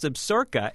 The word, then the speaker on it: Absaroka: Well, that's what I thought, too. It's actually pronounced Absaroka, and it's the best.